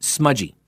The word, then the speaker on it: Smuggie: Smudgy is his nickname, but it's spelled Smuggy, S-M-U-G-G-I-E, but it's pronounced Smudgy.